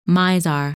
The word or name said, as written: Mizar